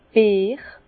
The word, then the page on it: d' Beer: Bas Rhin; Herrlisheim